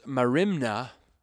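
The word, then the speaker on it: marimna (μέριμνα): It is the same Greek word, which is pronounced marimna, in the Greek.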